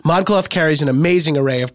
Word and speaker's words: ModCloth: ModCloth.